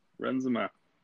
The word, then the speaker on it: Renzema: Yes, Renzema.